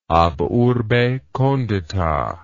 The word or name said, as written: ab urbe condita